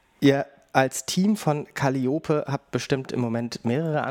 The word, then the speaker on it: Calliope: Ja, also Calliope wird tatsächlich Calliope ausgesprochen. Das ist die altgriechische Aussprache.